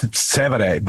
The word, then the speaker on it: Trastevere: Yeah, Trastevere. Priest for Life is, I can't say it right. Yeah. – And then there's certain. Trastevere. – I'll take your word for it. But that's Trastevere.